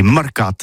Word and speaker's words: marcat: Eh bien, d'ici à toutis, mais le mercat de l'Uni ou le mercat des rebelles, mais en gascogne toulousano, c'est prône sur les marcats, avec un A, marcat. En gascogne toulousaine, bah, du côté, par exemple, de l'île des Baches, c'est l'île Jourdain, pour parler du marché, vous prononcez les marcats.